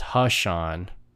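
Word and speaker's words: Ha'Sean: Like, not Dushan, it's Hashan. His name is like Hashan. It rhymes too.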